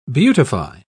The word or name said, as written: beautify